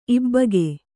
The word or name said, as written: ಇಬ್ಬಗೆ